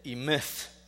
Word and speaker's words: Emeth: Emeth. E-M-E-T-H. Or phonetically spelled E-H-M-E-T-H, okay?